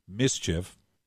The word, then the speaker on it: MSCHF: Nike sued the streetwear brand MSCHF, which you pronounce mischief, last week.